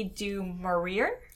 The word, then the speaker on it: du Maurier: But I should first start by saying that the book's Rebecca, and it is written by Daphne du Maurier. – Du Maurier. – Thank you. – It's French.